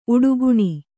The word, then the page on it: ಉಡುಗಣ: uḍugaṇa